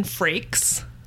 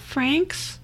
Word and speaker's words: Frakes: With Jonathan Frakes. – Are you sure it's not Franks? – Listen.